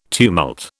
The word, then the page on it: tumult: British English